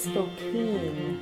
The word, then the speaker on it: Spokane: It's Spokane. – Oh, it is Spokane? – It rhymes with cocaine.